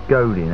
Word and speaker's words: Godin: And he said Dave Godin, actually. It's Dave Godin, actually. – Did I say Godin? Oh, yeah. Sorry, I mispronounced it.